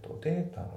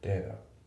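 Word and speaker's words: data: で、発音なんだけど、データのところの T がね、データ、まあ、 T っぽく発音されてますよね、データ。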